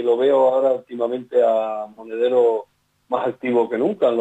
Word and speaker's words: Monedero: Ah, ese me equivoqué, lo pronuncié mal, Monederos, fue Juan Carlos Monedero quien aludió a esto.